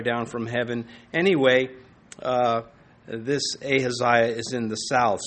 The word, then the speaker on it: Ahaziah: His name, Ahaziah, and pronounced a couple of different ways, but Ahaziah is how I will try to stick with it this evening.